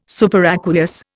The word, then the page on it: superaqueous: Indian